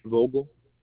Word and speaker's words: Vogel: Vogel.